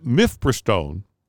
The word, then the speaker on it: Mifepristone: ruled that Mifepristone, which had been approved by the FDA for decades, and... – Nailed the pronunciation, by the way.